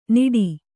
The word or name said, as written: ನಿಡಿ